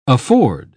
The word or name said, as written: afford